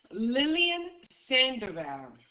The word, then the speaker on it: Lillian Sandoval: Lillian Sandoval. I can spell that as well. – Yes, please. – Lillian is L-I-L-L-I-A-N.